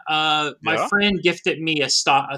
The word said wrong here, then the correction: Stadia: Is that how you pronounce it? – Stadia.